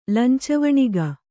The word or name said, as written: ಲಂಚವಣಿಗ